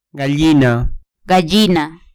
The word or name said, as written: gallina